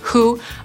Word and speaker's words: who: Tak samo, jeżeli słowo zaczyna się na zestawienie WH, to wtedy też W nie wymawiamy. Tak jest na przykład ze słowem kto, czyli who, albo czyj, czyli whose.